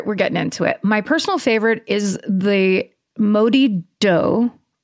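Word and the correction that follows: Moddey Dhoo: Oh, sorry. Pronounced Morty Doe.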